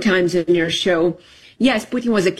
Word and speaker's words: Putin: There's only one person, Vladimir Putin. Do I say that correctly? – I think you're absolutely correct.